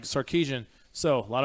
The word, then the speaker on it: Sarkisian: Sarkisian. – Sarkisian.